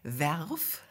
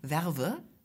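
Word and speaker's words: verve: Werf. mit Werf schreibt man e e, V-E-R-V-E, Werve, sagen auch manche.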